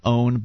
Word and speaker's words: pwn: Watch Me PWN, as in own, but spelled with a P.